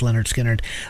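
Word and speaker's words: Lynyrd Skynyrd: Pronounced Leonard Skinner.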